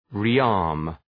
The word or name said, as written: rearm